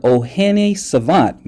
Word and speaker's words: Ohene Savant: We're talking to the good brother, OHENE SAVANT. Man, I know I tore that up. But Mr. You did it great. It was excellent.